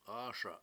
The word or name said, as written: AASa7